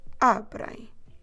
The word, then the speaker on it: abrem: Don't forget to stress the second to last syllable, a, in this case, abrem.